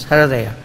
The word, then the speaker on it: Hridaya: Hridaya, the lakes or the large reservoirs of water. This is Hridaya. Hridaya. It should be pronounced as Hridaya.